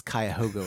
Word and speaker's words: Cuyahoga: I'm enough of an Ohioan still that I know how to pronounce Cuyahoga without having to look it up.